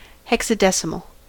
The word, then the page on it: hexadecimal: American English